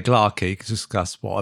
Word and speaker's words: Gla’aki: but they have Gla with the emphasis on the first syllable, Gla’aki, Gla’aki. – In the story, as we'll encounter a bit later, one of the characters makes reference to how it's pronounced, and it spells it phonetically in the story as G-L-A-R-K-Y, Gla’aki.